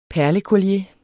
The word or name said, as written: perlecollier